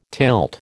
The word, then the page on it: tilt: American English